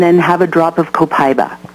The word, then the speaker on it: Copaiba: And, but you mentioned the CBD oil, and now doTERRA has brought out an essential oil from a plant, and it's called Copaiba, which is C-O-P-A-I-B-A, Copaiba, sometimes pronounced Copaiba, but we'll just go there.